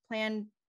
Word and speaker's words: Plantagenet: John was born a Plantagenet on, am I pronouncing that right? Plantagenet? – Yeah, there you go.